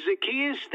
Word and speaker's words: Zacchaeus: My name is Zacchaeus. That's Zach-E-Us. Spelled with two C's and one Z.